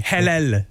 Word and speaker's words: halal: Halal? – Oui, halal.